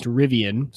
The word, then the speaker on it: Rivian: So there is a new kind of startup company that's starting up, and their new truck is, I believe it's pronounced Rivian, so R-I-V-I-A-N.